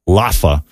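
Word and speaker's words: L'afa: And this time it's Laffa, which is easy enough. – Laffa. – Yeah, it's L apostrophe A-F-A. It's pronounced Laffa.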